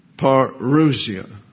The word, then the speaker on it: parousia: It's assigned with that definition the pronunciation of parousia. And so parousia is the English translation. Parousia is the Greek.